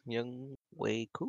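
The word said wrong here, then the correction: Younghoe: Young Ho.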